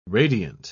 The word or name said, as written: radiant